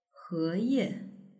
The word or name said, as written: heye